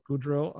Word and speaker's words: Goudreau: It featured former Boston members Barry Gordo. Is that how you pronounce that? – Goudreau On guitars and Brad Delp on backing vocals, as well as future Boston lead vocalist Fran Cosmo.